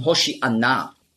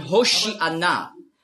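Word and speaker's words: Hoshianna: Hosianna, ja, ist einfach die griechische Aussprache von Hoshi'anna. Hosianna, das ist die griechische Aussprache. Weil die Griechen, die haben im Alphabet kein Sch-Laut. Nurz.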